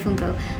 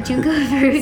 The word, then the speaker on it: Junko Furuta: I always want to say Funko. Junko Furuta.